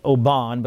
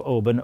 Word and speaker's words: Oban: Some people pronounce it Oban, but I've been to the town of Oban, O-B-A-N.